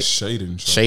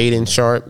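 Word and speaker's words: Shaedon Sharpe: Shannon Sharp, I don't know if I'm saying that right. – Shaden Sharp. – Shaden Sharp, yeah.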